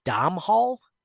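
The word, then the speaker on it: Domhnall: I believe it's Dom Hall. Dom Hall, I want to say. Yes, a Dom Hall. It's D O M H N A L L O'Neill.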